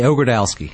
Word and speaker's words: Ogrodowski: Did I get that right? – You did pretty well. – How do you say it? – I say Ogrodowski.